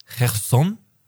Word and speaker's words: Cherson: Der richtige Aussprache ist Cherson. Cherson.